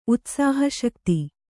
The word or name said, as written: ಉತ್ಸಾಹಶಕ್ತಿ